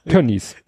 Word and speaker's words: Tönnies: Tönnies. – Irgendwas Tönnies. Ja. – ist ausgesprochen, nicht Is, sondern Yes.